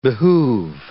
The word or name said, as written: behoove